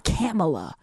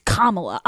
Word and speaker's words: Kamala: It's not Kamala. It's Kamala.